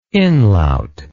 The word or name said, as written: inlaut